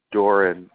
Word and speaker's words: Doeren: Doran, D-O-E-R-E-N.